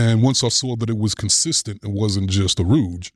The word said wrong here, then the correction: ruse: then, did I say that right? Ruse. – Good job. Ruse. You got it.